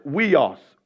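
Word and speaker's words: weos (υἱός): It's pronounced weos. Weos.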